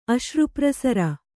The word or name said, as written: ಅಶ್ರುಪ್ರಸರ